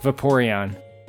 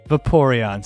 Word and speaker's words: Vaporeon: Happy, I think you're pronouncing that wrong. I can give the right pronunciation. – Please, well, no, okay, please. – Yeah. – Vaporeon. Vaporeon, Stevie.